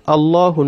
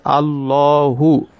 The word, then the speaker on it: Allahu: For instance, Allahu Nuru Samawati wal Arb. Allahu Nuru Samawati wal Arb. So you say Allahu, Allah, Allah. That is pronouncing the lamun with what? With the mufakham.